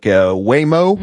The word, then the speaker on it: Waymo: They got considering legislation right now for legislation that would create a permit system for commercial driverless passenger vehicles, including robo-taxis like Waymo. Mm-hmm. Am I saying that properly? – Yeah.